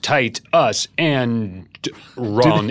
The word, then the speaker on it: Titus Andronicus: And Titus Andronicus, pronounced tight us and wrong.